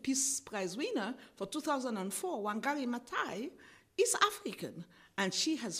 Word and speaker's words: Wangari Maathai: Wangari Maathai.